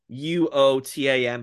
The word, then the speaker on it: Wotan: It's spelled Wotan, but it's pronounced Wotan because all of the monitors are actually named after writing gods from ancient cultures.